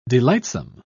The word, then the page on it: delightsome: British English